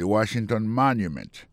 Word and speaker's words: Washington Monument: Si on a mis accent sur accent encore, on va voir que mot Washington, non, accent tombé sur le premier syllabe là.